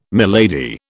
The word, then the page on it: milady: British English